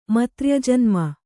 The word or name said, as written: ಮತ್ರ್ಯಜನ್ಮ